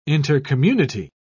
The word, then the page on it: intercommunity: British English